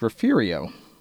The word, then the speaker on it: Refugio: As a story on the San Antonio newspaper's website on most pronounced Texas cities points out, there is also Refugio, which comes out of the mouths of those who know as Refugio.